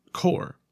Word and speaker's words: corps: The word corps means bodies in French and is also pronounced corps. Like in many French words where letters are written but not pronounced.